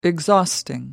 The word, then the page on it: exhausting: American English